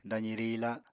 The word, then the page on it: dazi riila: apatani